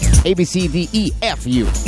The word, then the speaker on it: ABCDEFU: Casilla 16 viene perdiendo dos posiciones, y en la casilla número 15, nuestro tercer nuevo ingreso, se trata de Gayle con ABCDFAU. No lo dije mal. ABCDEFU.